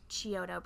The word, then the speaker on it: Chiodo: Keeping with the Chiodo brothers, that's how you say it.